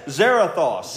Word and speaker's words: Zarathos: What's the other demon? Zathoro? The one you couldn't pronounce. Anyway. – Zarathos. – Zarathos. – Zarathos. Yes, Zarathos.